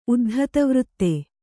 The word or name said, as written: ಉದ್ಧತವೃತ್ತೆ